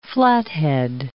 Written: Flathead

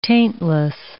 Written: taintless

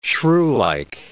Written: shrewlike

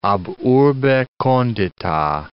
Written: ab urbe condita

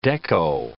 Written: deco

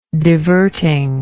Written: divertingly

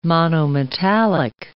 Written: monometallic

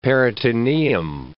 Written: peritoneum

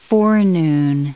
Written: forenoon

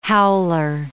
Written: howler

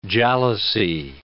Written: jalousie

